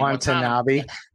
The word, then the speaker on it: Watanabe: Watanabe.